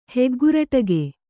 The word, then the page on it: ಹೆಗ್ಗುರಟಿಗೆ: Kannada